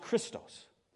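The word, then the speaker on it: Christos (Χριστός): Now, the original Greek word here for Messiah that's used here is pronounced Christos, which means Christ, right?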